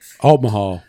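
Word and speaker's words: Altamaha: You know, he's gonna say it, and then wait, Altmahal or Altmahal? You know, Altmahal. – I just wanted to hear you pronounce the H in there, because I know it ain't the Altima.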